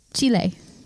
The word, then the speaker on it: Chile: Chile, if you want to like Chile. Have a Spanish pronunciation.